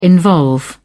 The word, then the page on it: involve: British English